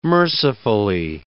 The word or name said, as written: mercifully